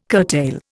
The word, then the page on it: curtail: Indian